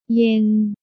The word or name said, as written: เย็น